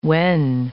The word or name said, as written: wen